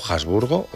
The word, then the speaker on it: Habsburgo: Formó parte de 9 gobiernos en España entre 1900 y 1923, alternando ministerios durante la regencia de María Cristina de Habsburgo, Habsburgo, Habsburgo, o como se diga en alemán. – Muy bien pronunciado, sí.